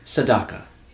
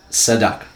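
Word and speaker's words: tzedakah: Sedeka. – Sedeka? – Yeah, Sedeka. – Okay, Sedeka. – A slight correction. The correct pronunciation is Sedaka. Sedaka.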